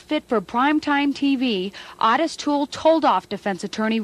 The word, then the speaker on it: Ottis: It's O-T-T-I-S, right? – Yeah. – Ottis is, I guess, how it's actually pronounced. Mm-hmm. I tried to make the same correction on Saturday when we were recording. – Yeah, it is pronounced Ottis Toole from what the book that I read.